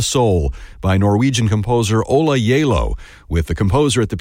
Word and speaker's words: Gjeilo: And if you're looking that composer up, his last name is spelled G-J-E-I-L-O, but pronounced Yalo.